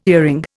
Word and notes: earring: Indian

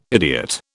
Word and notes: idiot: British English

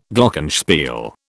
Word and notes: Glockenspiel: British English

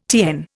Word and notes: TN: Indian

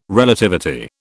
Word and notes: Relativity: British English

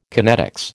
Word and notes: kinetics: American English

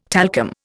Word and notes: talcum: Indian

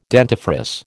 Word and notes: dentifrice: American English

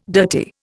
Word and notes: dirty: Indian

